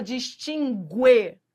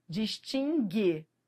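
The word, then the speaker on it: distingue: Você não fala distinguê. Você não fala. Se você fala, então não é para falar mais. Distinguê. Entendeu? Esse U não é pronunciado.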